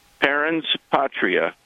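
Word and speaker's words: parens patriae: Perrin's Patria, or I think it may be pronounced.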